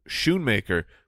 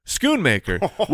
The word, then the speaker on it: Schoonmaker: I called him Luke Schoonmaker, which apparently it is, in fact, Schoonmaker, which makes the Scone Man reference even Scone Man.